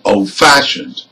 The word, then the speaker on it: old-fashioned: Another example. Old-fashioned. Again, this is a compound adjective and we stress fashioned.